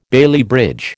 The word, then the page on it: Bailey bridge: American English